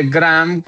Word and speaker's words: Graeme: Ya no va a estar más, no, mire, Graeme Lowton, no sé, el nombre Graham, se pronuncia Graham Lowton, que ese era el team principal de Cadillac, el que recibió el equipo prácticamente.